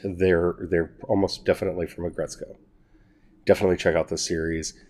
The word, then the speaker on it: Aggretsuko: I'm going to spell it for you just to make sure you can find it. It's A G G R E Ag Re Co. That's how it's spelled. It's pronounced Aggretsuko. So R, I'm sorry, A G G R E T S U K O. Aggretsuko.